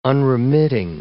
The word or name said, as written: unremitting